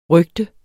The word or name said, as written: røgter